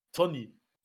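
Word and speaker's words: Toney: Ivan Tönnier? – Ja. Tönnier, Alter. Geile Aussprache dafür, dass er Engländer ist. – Tony. – Ja, Ivan Tooney.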